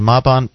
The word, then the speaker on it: Mabon: Mabon, pronounced Mabon, is the autumn equinox.